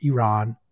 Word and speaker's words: Iran: It's pronounced Iran.